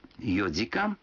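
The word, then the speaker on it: йодикан (四時間): Некоторые цифры в этих случаях произносятся не так, как обычно. 4 часа это йодикан, 7 часов ситидикан, а 9 часов кудикан.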